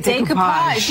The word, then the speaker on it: Decoupage: Decoupage.